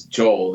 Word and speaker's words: Joel: Both are correct, actually. The English is Joel, the Hebrew is Yoel.